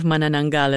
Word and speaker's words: Manananggal: And I gotta say, your pronunciation of Manananggal is quite impressive.